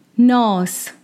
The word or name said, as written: Nadhr